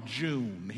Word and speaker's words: June: My name is Albert, and I'm a junior, so my family calls me Junior, but my granddaddy couldn't get the extra energy for the second syllable, so he just called me June.